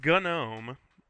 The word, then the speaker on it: GNOME: It is GNOME. – Okay. – Yeah, because it's was originally, in theory, part of the GNU project.